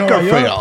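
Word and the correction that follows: Bakersfield: Se llama Bakerfield.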